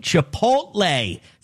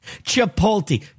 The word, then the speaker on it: Chipotle: Chipotle. Say it right. Don't say Chipotle. Okay? Chipotle. Chipotle. Say it one time.